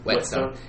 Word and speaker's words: whetstone: Whetstone. It's spelled like it should be Wheatstone, but it's Whetstone.